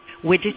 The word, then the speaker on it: Widgeteer: Widgeteer.